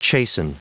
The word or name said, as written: chasten